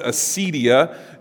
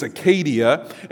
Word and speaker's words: acedia: The word that he uses there, we would pronounce it acedia in English, but in Greek it's acadia.